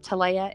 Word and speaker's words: Talea: And it's Brianna spelled the same way, but Talea is T-A-L-E-A.